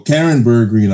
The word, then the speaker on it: Bergreen: Well, Karen Bergreen, I believe, is how you say the last name.